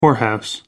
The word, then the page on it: whorehouse: American English